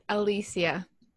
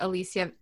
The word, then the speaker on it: Alicia: It's Alicia. – Alicia. – Right. It's okay, Spanish. – Okay. Oh, so it's like Alicia. – Mm-hmm. Yeah, it's just spelled, it's pronounced like the Alicia, A-L-I-C-I-A.